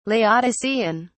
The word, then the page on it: laodicean: British English